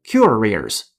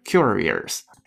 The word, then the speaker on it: curious: 不允许读成 curious，错了，它结尾是 o u s，是没有儿化音的，一定是 curious，curious，而不是 curious。